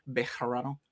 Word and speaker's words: Bejarano: Did I say that right? Bejarano. – Yeah, that's good.